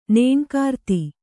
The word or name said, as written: ನೇಣುಕಾರ್ತಿ